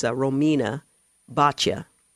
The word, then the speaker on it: Romina Boccia: It's Romina Boccia. is the correct pronunciation.